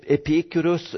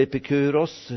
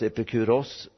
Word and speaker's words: Epikuros: Epikurus, eller Epikuros, hur den uttalas, Epikuros, han föddes 341 före Kristus på Samos och dog 270 före Kristus i Aten.